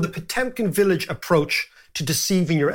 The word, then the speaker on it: Potemkin: And it's called Potemkin villages, or Potyomkin, as the Russians would pronounce it, villages.